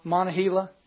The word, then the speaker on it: Monongahela: Monongahela.